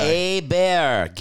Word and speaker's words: Hebert: Let's talk about this Bobby Herbert guy. – Hebert, give it the French pronunciation. Hebert.